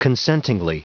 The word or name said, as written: consentingly